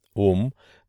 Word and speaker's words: ом: «А» произносится открытым горлом, «У» переносит звук вперед, а несколько назализированное, «М» произношение в нос, примечание редактора, подводит его к самим губам.